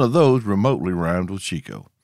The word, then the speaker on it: Chicot: But speaking of Chico, it's spelled C-H-I-C-O-T. And here's a pro tip for you. If you ever find yourself working undercover in Arkansas, the quickest way to let folks know you ain't from the natural state is to pronounce Chico as Chicot, El Dorado as El Dorado, and Nevada as Nevada.